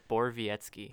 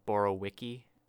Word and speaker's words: Borowiecki: Borowiecki. – Borowiecki. – I don't know how you get that out of like Borowiki. I guess it's just Polish or something.